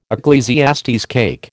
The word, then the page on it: Eccles cake: American English